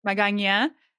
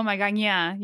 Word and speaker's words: Magaña: Alex Magaña, right? That's how you say your name, right? – Yeah, yeah. Don't forget about the accent mark on the end. – I know, Magaña, you know, the end.